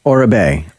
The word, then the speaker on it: Oribe: How do you pronounce it? – Oura-Bay. O-R-I-B-E.